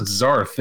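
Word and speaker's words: Zearth: They pronounce it Zarth in the movie.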